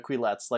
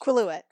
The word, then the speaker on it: Quileute: Quileutes. Like we, or no, sorry, how is it pronounced? – Quileute.